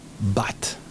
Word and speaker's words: but: Pero se dice but. B-U-T. But. La U se va a pronunciar como una A española, pero muy cortita.